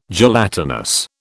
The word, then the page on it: gelatinous: British English